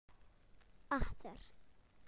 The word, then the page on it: behind: British English